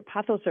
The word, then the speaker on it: pothos: This listener sent a text that said, I was recently in Florida and brought back, is it pronounced pothos? – Pothos vine, yep.